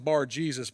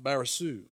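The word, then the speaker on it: Bar-Jesus: Now, when you look at it, it looks like Bar Jesus, but it's actually pronounced Barisu.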